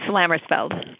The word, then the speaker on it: Lammersfeld: It's Lammersfeld.